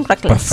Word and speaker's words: Reckless: El otro extremo, Aura y Reckless. Reckless, lo dije bien, Reckless. – Perfecto.